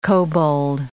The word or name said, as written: kobold